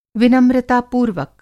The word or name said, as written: विनम्रतापूर्वक